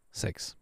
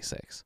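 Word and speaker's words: Seix: It's French, and it's pronounced sex. So exactly as you imagine, but it's not written like that. It's S-E-I-X. Sex. But you say sex.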